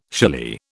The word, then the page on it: shily: British English